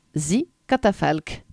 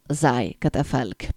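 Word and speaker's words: Thy Catafalque: du groupe de métal avant-gardiste et expérimental hongrois The Katafalk, ou devrais-je dire plutôt Zai Katafalk, parce que ça s'écrit T-H-Y.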